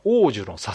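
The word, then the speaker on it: 桜樹: こちらタイトルが、王樹の囁きという、桜の木と書いて王樹と読むんですけど。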